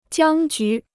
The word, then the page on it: 僵局: jiāng jú